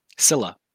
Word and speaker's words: Scylla: You know, like a Skyla? Yeah, how do you say the water variant Hydra? – Scylla. – So a Scylla, so Nursilla.